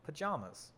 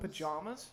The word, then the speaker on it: pajamas: How do you pronounce the second syllable of pajamas? With the vowel in jam? – In the palm. – Did you say pajamas? – Pajamas? – Pajamas.